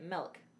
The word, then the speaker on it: milk: No, I can say it right. Milk. Right? – Yeah. – Sounds funny. I have to really focus on not leaning into that A.